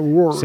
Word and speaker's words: Wars: Wars. – Pronuncies molt bé, eh? Wars.